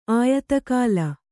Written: ಆಯತಕಾಲ

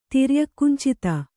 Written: ತಿರ್ಯಕ್ಕುಂಚಿತ

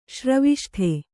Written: ಶ್ರವಿಷ್ಠೆ

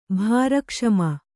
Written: ಭಾರಕ್ಷಮ

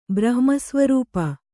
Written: ಬ್ರಹ್ಮಸ್ವರೂಪ

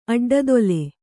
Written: ಅಡ್ಡದೊಲೆ

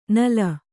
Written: ನುಲಿ

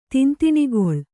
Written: ತಿಂತಿಣಿಗೊಳ್

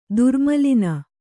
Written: ದುರ್ಮಲಿನ

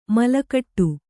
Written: ಮಲಗಟ್ಟು

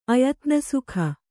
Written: ಅಯತ್ನಸುಖ

